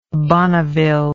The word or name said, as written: bonneville